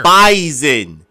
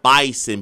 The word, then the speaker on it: Bison: It's Bison. – Well, that's why you're here. – B-I-Z-O-N, not Bison. B-I-S-O-N.